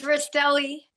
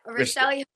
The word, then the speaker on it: Rastelli: It's Rastelli. Yep, Rastelli.